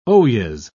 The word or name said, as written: oyez